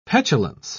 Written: petulance

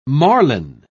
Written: marline

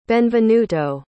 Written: Benvenuto

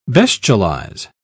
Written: bestialize

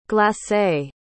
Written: glace

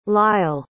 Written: lyle

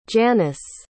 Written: Janis

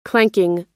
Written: clanking

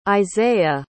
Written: Izayah

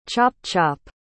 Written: chop-chop

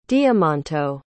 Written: diamanto

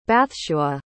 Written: bathshua

